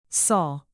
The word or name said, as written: saul